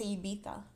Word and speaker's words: Ibiza: The way you're saying Ibiza. – Well, that's how they say it on the show. – I know. Us Americans say Ibiza. I know. Ibiza. – But in the UK, they say Ibiza.